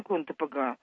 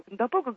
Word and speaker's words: Кондопога: Это финно-угорское название, и ударение в Карелии ставят на первом слоге. Железнодорожные службы тоже делают это ударение. Правильнее ударение Кондопога. Оно немножко необычно для нашего восприятия. Люди далекие от этих мест, они, как правило, Кондопога говорят. Но, в общем, правильнее Кондопога.